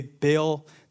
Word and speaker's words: Baal: And your father's house, because you have abandoned the commandments of the Lord and followed the Baals. By the way, Baal, that's the American way to pronounce it. The Hebrew way is Baal.